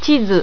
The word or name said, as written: chizu